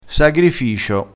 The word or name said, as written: sagrificio